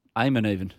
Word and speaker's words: Eamon: Eamon. – I was really reading the E. – Eamon. Loved his dance festivals. – A silent E.